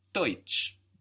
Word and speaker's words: Deutsch: Deutsch is spelled D E U T S C H. So EU in German gives us oi. It gives us this oi sound.